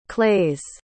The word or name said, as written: claes